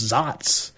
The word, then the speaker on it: Saaz: The Zotz hop is the one that's spelled S-A-A-Z.